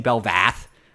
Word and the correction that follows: Belveth: a lot during one of the casts, and I got some feedback like, no, it's Belveth. But yeah, I need to pay attention to those new champions' pronunciations. Belvath. It's Belveth, I'm pretty sure.